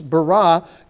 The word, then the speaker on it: bara: And in Hebrew, the emphasis always goes on the final syllable of a word, unless you add endings onto it. So it's not bara, it's bara. God is the one who created.